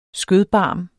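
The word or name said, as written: SKØDBARM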